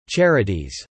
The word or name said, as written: charities